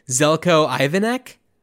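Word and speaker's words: Željko Ivanek: So Mr. Cleary is played by, and Dan, if you have a better pronunciation than I do, Zelko Ivanek. – That's pretty much, yeah.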